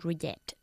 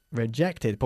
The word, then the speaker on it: rejected: I regret to tell you that your application has been rejected. – Rejected. ¿Por qué decimos rejected? Porque el verbo reject acaba en una T y por eso pronunciamos la E final.